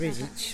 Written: bigity

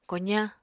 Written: kozañ

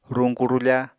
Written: ruñku-rufa

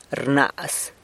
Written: rnaz

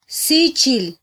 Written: sichil

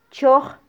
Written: choj

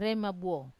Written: rema buo

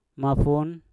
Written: mafun

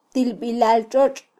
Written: tilbilaltxotx

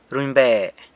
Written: runybe

